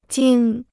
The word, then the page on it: 惊: jīng